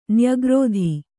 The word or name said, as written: ನ್ಯಗ್ರೋಧ